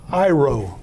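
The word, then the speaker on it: iro (αἴρω): This Greek word right here, it's pronounced iro, right?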